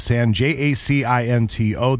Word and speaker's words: San Jacinto: I've heard of San Andreas, but the San, J-A-C-I-N-T-O,